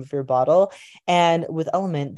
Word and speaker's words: LMNT: My most recently preferred electrolyte supplement is Element. It's spelled L M N T if you look it up. The reason that I really prefer Element is because because of the ratio of electrolytes and the saturation density of electrolytes alongside how the formula dissolves.